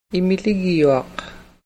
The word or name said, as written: immiligyuaq